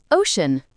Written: ocean

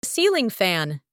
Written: ceiling fan

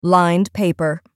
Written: lined paper

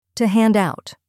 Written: to hand out

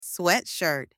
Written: sweatshirt

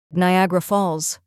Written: Niagara Falls

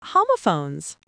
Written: homophones

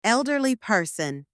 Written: elderly person

